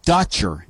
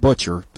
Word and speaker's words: Dutcher: And Brian Dutcher, D-U-T-C-H-E-R, not Butcher. I always mess his name up. I want to call him Butcher, too, guys.